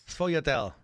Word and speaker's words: sfogliatelle: Sfogliatelle. – Close. – Sfogliatelle.